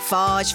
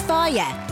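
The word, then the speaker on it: Fage: We've heard it all. Farge, farge, phage. But it's pronounced Fire. Seriously, Fire. It means to eat in Greek.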